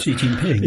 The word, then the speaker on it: Xi Jinping: and we have it's Xi Jinping, is that how you say it? I'm bad at this. – Xi Jinping, yeah.